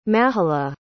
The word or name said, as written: mahalah